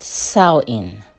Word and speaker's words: Samhain: Reclaim the real Halloween, the ancient festival of Samhain. Is that how our Halloween started? – It's pronounced Samhain, but yes.